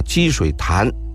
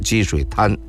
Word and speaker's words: 积水潭: 说这个积水滩啊，这我又得它这个正确的读音应该是潭啊，积水潭，但是北京人一般的都说积水滩因为咱们这本典故北京也是用京味语言来写的所以有些词儿我呢最好还是用这个京味语言吧。